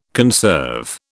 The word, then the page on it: conserve: British English